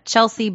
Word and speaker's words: Chelsea: And you can find me on Instagram at Chelsea Blue Moon, C-H-E-L-S-E-A-B-L-U-E-M-O-O-N.